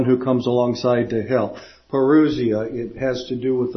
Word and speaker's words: parousia: But this happens to be the word parousia.